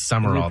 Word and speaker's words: Sumrall: He became part of S-U-M-R-A-L-L. – I know, it's weird. It is Summerall, though. – Is it pronounced Summerall? – Yeah. – I feel like there's a letter missing. – It is. It's not spelled right, but that's how it's pronounced.